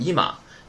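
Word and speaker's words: いま: 今天单词生词表第一个单词读成伊玛。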